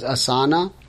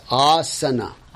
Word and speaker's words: asana: Correction in pronunciation. Please do not pronounce it as asana. It's asana.